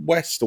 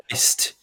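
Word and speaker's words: Wiest: Also has in it, Vincent Price plays the old professor, Diane West, or Weist, I think it's pronounced actually. – I think it might be Weist.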